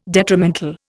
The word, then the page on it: detrimental: Indian